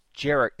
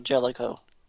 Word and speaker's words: Jellicoe: And then, by the way, is it Jellicoe? Admiral Jellicoe? Or is Jericho? Is that a different character? I'm not familiar with it. – No, it's the same guy. Did I mispronounce it? Is it Jericho or Jellicoe? – I thought it was Jellicoe. I could be wrong.